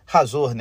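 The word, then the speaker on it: Hazor: Então todo o mapa ali que a gente vê no Novo Testamento, da área da Galiléia onde foi o ministério de Jesus, Azor era a cidade, Razor, né, que seria a pronúncia certa,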